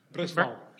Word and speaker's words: Breslau: He was born in, how do you pronounce this, Breslau, Germany? – Breslau. – What's that? – Breslau. – Breslau, okay, just like it looks, right?